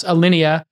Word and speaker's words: Alinea: Obviously, you know, there's, I think, Alina, am I pronouncing it correctly, in Chicago? – Alinea.